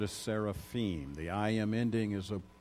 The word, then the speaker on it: seraphim: Really, it's pronounced seraphim. Most of us will say seraphim. And that's okay if you want to say seraphim.